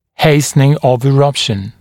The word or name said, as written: hastening of eruption